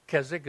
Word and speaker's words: Keswick: I told you how to pronounce that, so don't do it otherwise. Keswick is done without the W.